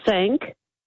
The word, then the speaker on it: Cinque: It's not Cliquet. It's Sank, and it's C-I-N-Q-U-E. It's order of the Sank ports. – Okay. – Well, no, it's pronounced. Well, you could pronounce it sink ports, but it's C-I-N-Q-U-E. – Right, C-I-N-Q-U-E.